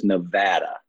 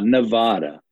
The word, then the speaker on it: Nevada: It's Las Vegas, Nevada, not Nevada the day you move to Las Vegas, they teach you that, and you will know if you're local or tourist based on how you pronounce it.